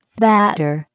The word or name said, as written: batter